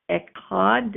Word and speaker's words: echad: So in this verse, the word one is the Hebrew word echad.